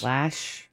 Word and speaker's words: Loesch: Lash. – Or Loesch. Yeah. L-O-E-S-C-H. – Yeah, it's pronounced Lash, I think.